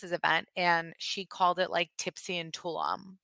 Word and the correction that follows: Tulum: It's Tulum. Like, the way you get, like, every city or country wrong, and I told you last time you said it wrong.